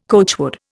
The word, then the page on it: coachwood: Indian